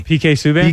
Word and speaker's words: Subban: Subban, how you pronounce his name? – PK Subban. – PK Subban. I can make sure I pronounced it right.